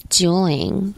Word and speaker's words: Juuling: It's J-U-U-L-I-N-G. Juuling. Maybe I'm saying it wrong. – I read it as Juuling. – I think when you have two U's next to each other, it's not a W. I think it's an U.